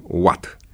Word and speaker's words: watt: Scriem Vat, cu dublu T, cum spuneam, și cu dublu V, dar pronunțăm Watt.